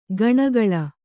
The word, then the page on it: ಕಣೆಗೊಳ್: kaṇegoḷ